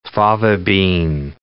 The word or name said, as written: fava bean